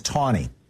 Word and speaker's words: Taney: Except, by the way, his name was pronounced Tawny. There was no W, but that's how they pronounced it.